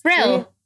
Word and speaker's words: Rhyl: Is that how you say it? – Rhyl. – Rhyl. – Yeah.